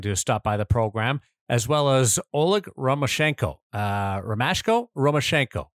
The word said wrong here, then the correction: Romashko: Well, I don't think there's an N in there, so it's just Romashko to me.